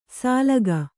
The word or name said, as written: ಸಾಲಗ